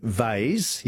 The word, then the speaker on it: vase: And Robert and Mitch and David in Adelaide, we say vase, yes, V-A-S-E, rhymes with haze, but we also say Lego for Lego, L-E-G-O.